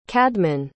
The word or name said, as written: cadman